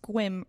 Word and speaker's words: Sequim: So it says Sequim, Washington, but he says it's pronounced Squim, rhymes with whim.